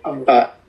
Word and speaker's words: Amaravati: Amaravati, I'm saying it wrong, sorry. – Amaravati.